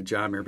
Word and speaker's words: Jahmyr: We're not pronouncing it Jahmyr, because that's how he pronounces it, and I've now heard teammates pronounce it that way. Oh. And with the H in there, it kind of makes sense.